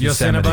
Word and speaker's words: Yosemite: You didn't say it right, but we know what you meant. Yes, Yosemite.